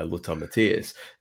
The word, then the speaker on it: Lothar Matthäus: Just one thing before I pass over, just about that how interesting that, that Barry Davies's pronunciation of uh, Lothar Matthäus is Lothar Matthäus.